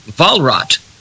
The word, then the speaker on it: Walrath: My middle name is, you pronounce your W as a V, Werner. My middle name is Walrat with a W. And that name, Walrath, if you don't use a German pronunciation, that is my German family name fleeing the 30 years war in the Rhineland, in Niedersachsen.